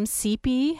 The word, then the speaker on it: Cipe: Yeah, CP is spelled C-I-P-E, but pronounced like the letter C-P, which is also her initials.